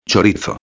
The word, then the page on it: chorizo: espanhol